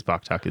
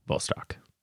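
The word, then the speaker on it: Vostok (Восток): Sometimes you see this on the dial written Boktok. That's Cyrillic. It's actually what you see as Boktok is pronounced Vostok.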